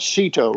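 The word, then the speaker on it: SEATO: The United States, going back to the 50s, was involved in an organization called CETO, rhymes with NATO, Southeast Asia Treaty Organization.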